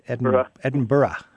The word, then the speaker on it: Edinburgh: I used to always say Edinburgh, but you don't want to pronounce that G on the end. It's Edinburgh.